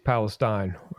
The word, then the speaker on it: Palestine: You see, it's actually since I lived in that area for quite a while, it's actually East Palestine.